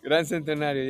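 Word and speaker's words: Gran Centenario: Say it correctly. So I can hear it. – Grand Centenario, yes.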